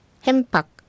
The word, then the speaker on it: ヘンパク: 次にヘンパク、ヘンパク、発音注意してくださいね。ヘンで、ローマ字の M の発音になるので口は必ず閉じてください。 – はい、そして最後のクははっきりと発音せず小さいクの発音ですので、舌はどこにもついていないクの発音ですね。